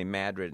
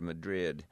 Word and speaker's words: Madrid: Well, Madrid would fit into that category too, you know, knowing that we say Madrid instead of Madrid, that we could work on one for there.